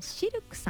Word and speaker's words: CILQ: そして南青山にフレンチレストランシルクさん、 CILQ と書いてシルクさん、